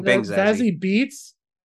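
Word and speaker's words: Zazie Beetz: Am I saying her name wrong? Bang Zazie Beats.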